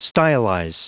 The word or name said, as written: stylize